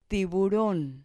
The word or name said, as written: tiburon